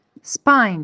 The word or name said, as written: spine